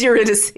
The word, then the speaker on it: Eurydice: But like, it's kind of one of those like pedantic things where it's like Eurydice is the anglicized, should be a K, Eurydice. So I think like if you want to be, you know, correct and pedantic, it should be Eurydice, but in the show they pronounce it Eurydice.